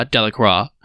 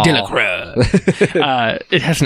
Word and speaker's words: Delacroix: It's his name's hard to pronounce. It's Delacroix. – Delacroix. It has an X in it, and it's just so unnecessary. Like, what's up with French? – It's French.